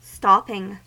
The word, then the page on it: stopping: American English